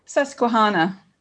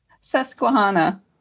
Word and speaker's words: Susquehanna: How do you say that word? – Susquehanna. – So I can't say that. Say that again. – Susquehanna.